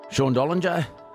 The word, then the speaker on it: Dollinger: Sean Dollinger, I hope I pronounced that right.